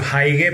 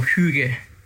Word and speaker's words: hygge: yo la pronuncio Heige, pero es H Y G G E. Ajá. ¿Y es, eso cómo lo pronuncias? Y la Y se lee como una U, sería algo así como Hüge.